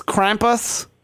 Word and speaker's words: Krampus: Krampus. – But it's spelled K-R-A-M-P-U-S.